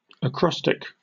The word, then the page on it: acrostic: British English